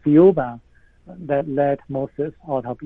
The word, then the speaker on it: Thiaoouba: Thiaoouba or Thiaoouba, the pronunciation is very similar to Jehovah.